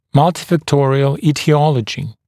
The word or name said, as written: multifactorial aetiology